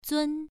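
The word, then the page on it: zun: tone 1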